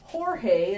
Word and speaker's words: Jorge: That is Jorge is on the right. It's spelled George, J-O-R-G, but they never pronounce the letter J in anything that they have.